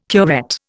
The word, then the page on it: curette: Indian